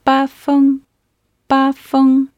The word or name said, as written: bafeng